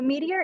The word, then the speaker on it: Meeteor: So Meteor is the business that I said it wrong. – No worries. So Meteor, we are a little play on words because it's M-E-E-T-E-O-R.